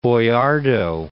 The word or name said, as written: Boiardo